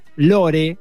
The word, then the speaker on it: Lore: Después hay otra que está en Amazon, guarda con el buscador porque vamos a pronunciar como se dice, Lore, o sea, Lore, una, es, basada en un podcast del mismo nombre, el podcast conocido de Aaron Manke.